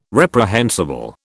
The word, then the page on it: reprehensible: British English